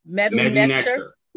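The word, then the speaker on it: Medu Neter: Madu Netcher. Is that how you pronounce it? – Madu Netcher. Right.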